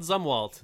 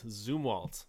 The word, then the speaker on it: Zumwalt: Zumwalt, that's what it is. Not Zumwalt, Zumwalt. So that's how we pronounce that.